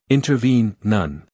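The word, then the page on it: intervene: uk ˌɪntəˈviːn; en-us ˌɪntərˈviːn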